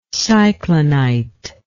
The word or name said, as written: cyclonite